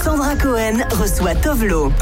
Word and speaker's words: Tove Lo: The exact is Tove Lo. – Tove Lo.